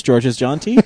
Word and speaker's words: Georges Jeanty: And so We talked to George's, and we found out his name is pronounced George's Jeanty, which we are always really concerned about.